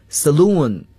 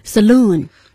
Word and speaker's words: saloon: 在美国，它的发音呢要读作 saloon。 – saloon。